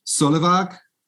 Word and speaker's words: Solevåg: I've been practicing in my head and I've already forgotten how to say it, but it's Rebecca Solevåg. Is that correct? – Yes. – Is that close enough? – Yes.